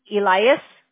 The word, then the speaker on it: Elyass: Elias, E-L-Y-A-S-S. – E-L-Y-A-S-S. Elias? – Yeah.